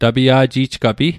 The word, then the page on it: tapia'ji'jgapi: Mi'gmaq